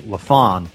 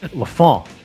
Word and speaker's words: Lafon: You clarified the pronunciation of his name, because it's something, I mean, he basically told you on that one episode that you can say Mitch LaFon, which I've been trying so hard to get that, you know, French Montreal Canadian LaFon, you know, been trying so hard to say it like that.